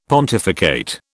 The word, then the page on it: pontificate: British English